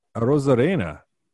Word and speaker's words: Arozarena: Speaking of bonkers numbers, Arozarena.